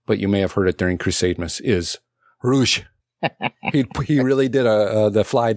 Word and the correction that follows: ruse: We talked about this. – Oh. – Say it right. – Ruse. – There we go.